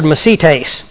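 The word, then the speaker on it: mesites (μεσίτης): The word mediator that Paul uses in 1 Timothy chapter 2 and verse 5 is the Greek word mesites. It's a very interesting word. M-E-S-I-T, long E-S.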